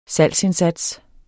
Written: salgsindsats

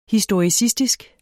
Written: historicistisk

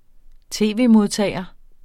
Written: tv-modtager

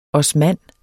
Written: OSMAN

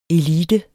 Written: elite

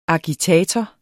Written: agitator

